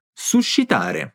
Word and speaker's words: suscitare: Cuidado em pronunciar bem este grupo sh nessa palavra, suscitare.